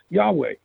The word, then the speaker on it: Yahweh: There are four Hebrew letters, Yad, He, Waw, and He, pronounced Yahweh,